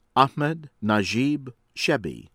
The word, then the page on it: Ahmed Najib Chebbi: American English